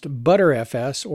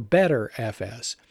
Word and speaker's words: Btrfs: The next one is BTRFS, or B-Tree File System, or oftentimes pronounced ButterFS or BetterFS.